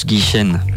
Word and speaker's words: Guichen: T'as vu tout à l'heure j'ai dit Jean-Charles Guichard, mais c'est notre invité Gwen de Kemp qui m'a dit ça se prononce Guichen.